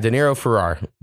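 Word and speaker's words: Deniro Farrar: And the name of this artist, I'm going to say it's pronounced De Niro Farrar. Yeah, De Niro Farrar. Actually, it's spelled D-E-N-I-R-O and then F-A-R-R-A-R.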